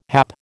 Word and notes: hap: American English

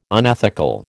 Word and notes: unethical: American English

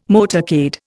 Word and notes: motorcade: Indian

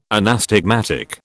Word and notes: anastigmatic: British English

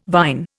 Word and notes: vine: Indian

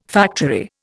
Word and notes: factory: Indian

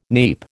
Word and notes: neap: American English

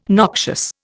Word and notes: noxious: Indian

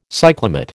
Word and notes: cyclamate: American English

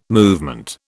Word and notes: movement: British English

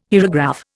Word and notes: hierograph: Indian

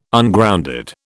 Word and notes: Ungrounded: British English

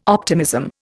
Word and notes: optimism: Indian

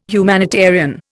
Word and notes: humanitarian: Indian

